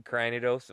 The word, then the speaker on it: Cranidos: Cranidus. – Sure. – Cranidos. What is that? I'm saying it right? – Yeah, that's from Gen 4 fossil.